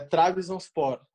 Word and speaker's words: Trabzonspor: E é Trabzonspor o nome, né? – Tem ah. muita gente que se pega um pouquinho na pronúncia, mas eu treinei bastante, então já tô craque.